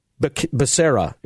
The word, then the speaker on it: Becerra: You remember this guy, Xavier Becerra.